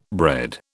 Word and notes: bred: British English

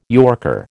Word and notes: yorker: American English

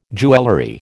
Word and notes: jewellery: American English

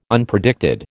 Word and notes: Unpredicted: American English

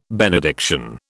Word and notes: benediction: British English

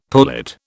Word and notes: pullet: British English

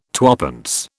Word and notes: twopence: British English

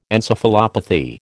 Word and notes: encephalopathy: American English

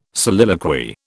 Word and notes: soliloquy: British English

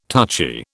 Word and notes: touchy: British English

